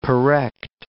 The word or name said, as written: porrect